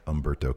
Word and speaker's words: Humberto: I've never pronounced Humberto with an H. I know it's spelled that way, but it's not pronounced that way. – Yeah. – Humberto Carrillo.